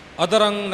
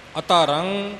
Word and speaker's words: अतरं: अतरं गुरमुखा ने जिवें उच्चारण किया है इसे तरह इसे प्रकार ही करना है अधरंग नहीं इथे बोलना अतरं तरं तारण है